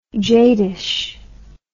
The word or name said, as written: jadish